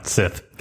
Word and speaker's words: Sith: Sith.